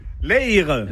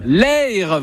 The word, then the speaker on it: Eyre: Merci beaucoup, le mot de la fin qui a très peu parlé aujourd'hui parce qu'il tient à ce qu'on prononce très bien cette rivière qui passe à côté. On dit que c'est... – L'Eyre. – L'Eyre, voilà, ça c'est l'accent. Merci beaucoup.